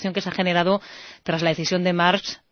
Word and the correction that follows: Maersk: Pues con Maersk. – Maersk, eso. Yo me empeño en decir Marx y es Maersk.